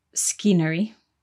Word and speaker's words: scenery: So scenery is spelt S-C-E-N-E-R-Y. But the C is silent at the beginning. So we don't say scenery, we say scenery.